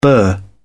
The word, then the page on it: bur: British English